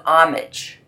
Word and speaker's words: homage: It's a place of homage. Right? You don't pronounce the H there. I'm also using a bit of assonance, repeating the word ah sound in five places.